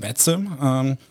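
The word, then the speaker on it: VATSIM: Sprecht es überhaupt richtig aus oder spricht man das WattSim aus? – Ja, die Amerikaner eher WattSim, aber WattSim ist ein Online-Flugnetzwerk, wo Leute, die daheim mit ihren Flugsimulatoren fliegen, online zusammen mit anderen Leuten fliegen können.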